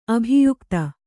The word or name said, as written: ಅಭಿಯೋಕ್ತೆ